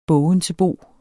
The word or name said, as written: bogensebo